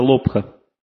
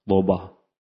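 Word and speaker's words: лобха (लोभ): Следующий враг человека называется лобха или лобба. Там иногда ха не произносится в санскрите, то есть там придыхательный звук ха, лоба, так, крода.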